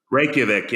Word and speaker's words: Reykjavik: But how do you say it? Reykjavik. – Reykjavik, yeah.